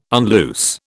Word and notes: unloose: British English

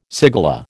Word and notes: cigala: American English

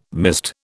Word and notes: mist: British English

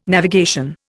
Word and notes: navigation: Indian

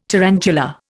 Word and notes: tarantula: Indian

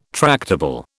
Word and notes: tractable: British English

